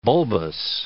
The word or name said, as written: bulbous